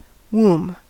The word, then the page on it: womb: American English